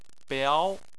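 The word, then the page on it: biao: tone 2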